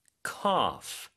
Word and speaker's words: cough: I have a pretty bad cough. C-O-U-G-H. – 네. C-O-U-G-H이고요. G-H가 이렇게 끝에 올 때는 보통 F로 발음을 하죠. So cough이고요.